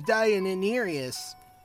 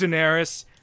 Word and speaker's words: Daenerys: I've seen your poster of Deianirius. – It's pronounced Daenerys.